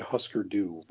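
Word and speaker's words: Hüsker Dü: So I discovered Husker Du very late in life. Is that even how you pronounce it? – Yeah.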